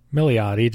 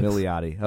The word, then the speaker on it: Milioti: Milioti, just like it looks. – Milioti, okay.